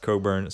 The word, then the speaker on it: Cockburn: It's spelled Cockburn. Okay, get your giggles out. – I got my giggles out. – It's pronounced Cockburn, or so he says.